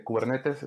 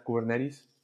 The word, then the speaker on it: Kubernetes: Eh, aquí cuando hablamos, por ejemplo, con, los clientes, eh, se pronuncia, bueno, en los congresos también de, Kubernetes en español, en inglés sería Kubernetes.